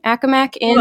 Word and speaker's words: Accomac Inn: And today she is here with us on the Roadside Podcast to talk about the Accomac Inn. Am I saying that right? Accomac Inn.